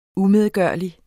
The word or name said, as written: umedgørlig